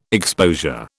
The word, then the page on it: exposure: British English